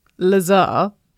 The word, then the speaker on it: Lazar: Okay, so it is pronounced Lazar, but it's spelt laser.